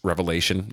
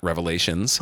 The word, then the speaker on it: Revelation: It's Revelation, not Revelations. Most people do say it wrong.